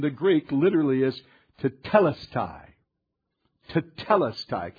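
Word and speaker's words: tetelestai (τετέλεσται): Now, you might have heard that pronounced differently. And some say tetelestai, et cetera, pronunciations. But for the purpose of this morning, where we're going, tetelestai is what I'm going to use.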